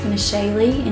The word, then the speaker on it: Meshali: So on Instagram, on Twitter, on Facebook, I'm pretty active on all three, mainly Instagram, but it's just at Meshali, and you spell it M-E-S-H-A-L-I. – We'll link to all that in the show notes.